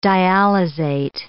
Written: dialyzate

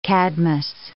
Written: Cadmus

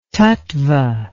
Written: tattva

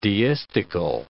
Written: deistical

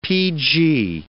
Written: PG

